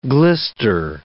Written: glister

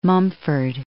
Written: Mumford

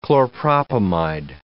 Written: chlorpropamide

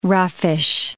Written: roughish

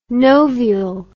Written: novial

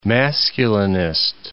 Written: masculinist

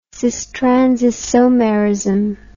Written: cis trans isomerism

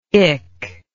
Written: ick

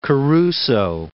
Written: Caruso

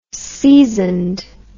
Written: seasoned

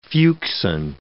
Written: fuchsin

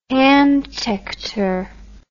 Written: handtector

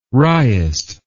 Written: wriest

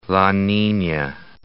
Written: La Nina

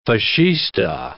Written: Fascista

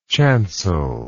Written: chancelled

